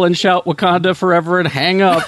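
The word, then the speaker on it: Wah-Kon-Tah: In Osage, the word is typically written as W-A-H, period K-O-N, period T-A-H, although it is pronounced Wakanda.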